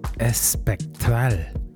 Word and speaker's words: Espectral: On top of this, Ken Zhu with Espectral, Espectral, that's how you say it, on Deep Clicks from Spain.